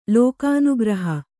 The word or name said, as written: ಲೋಕಾನುಗ್ರಹ